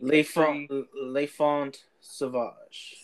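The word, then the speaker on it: L'Enfant Sauvage: It's La Infante Save. I think it's the brown one that it looks like. – Le Font Sauvage. – Sauvage. Okay, yeah.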